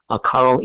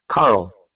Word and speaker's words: Carl: Carl Easel. – Spell that for me, please, sir. – Carl, C-A-R-L, Easel, E-Z-O-L-D, as in dog.